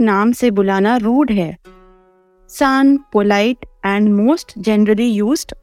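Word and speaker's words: सान (さん): रोमा जी में सान की स्पेलिंग है S A N N for Nagpur इसकी प्रोनाउंसिएशन फिर से रिपीट करती हूँ सान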